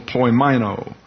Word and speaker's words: poimaino: P-O-I-M-A-I-N-O. Long O, poimeno.